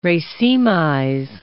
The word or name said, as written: racemize